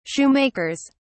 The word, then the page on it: shoemakers: British English